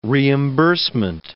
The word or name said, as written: reimbursement